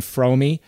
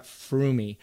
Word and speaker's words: Froome: And maybe I should actually apologize to Chris Froome right now because since the day that I've coached him, I've mispronounced his last name. I've always called him Fromy. – So like um, when people say, "Hey, you know this guy for so long, but you keep pronouncing his name. And I'm like, I'm sorry, it's just ingrained in my head. Fromy, Fromy, Fromy, not Froomy.